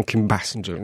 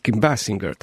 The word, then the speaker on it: Kim Basinger: bardzo często ludzie mówią Kim Basinger, nie? Mhm. A to jest Kim Basinger, tak? – Aha.